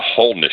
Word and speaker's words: holiness: Uh, I, I won't be bothering to go in a Reformed Baptist Church or a, a a wholeness church, which is how holiness is pronounced where I live, wholeness church.